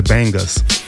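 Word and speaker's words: Bangas: That was coming from, what is it, Tank and the Bangles. Bangus. The Bangus, yeah. Pronounce it correctly, Chucky. Pronounce it correctly. Tank and the Bangus.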